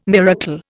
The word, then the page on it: miracle: Indian